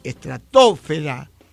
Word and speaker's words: estratosfera: De todas ellas, solo la palabra atmósfera es esdrújula. Las demás son graves y llevan el acento sobre la sílaba fe. Estratosfera, ionosfera, biosfera, litosfera o hidrosfera.